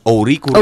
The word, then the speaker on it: Ouricuri: Bueno, acento en la I. Ouricuri.